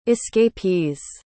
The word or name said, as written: escapees